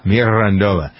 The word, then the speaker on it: Mirandola: Mirandola.